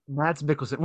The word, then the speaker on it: Mads Mikkelsen: Yeah, I mean, and go back to Casino Royale, Mars Mouth Milkins, I ruined his name. – Lars! – It's Mads. I know I'm so bad at names. Pronounce it for me. You're better at it. – Mads Mikkelsen.